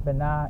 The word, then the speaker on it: Bana: And Tuesday, Bana, is pronounced Bana in the Tri language of Ghana and the Akan tradition.